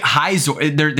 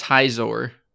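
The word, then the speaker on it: Khayzur: Wait, Hizor, the K is silent? – Yeah, it's Hizor.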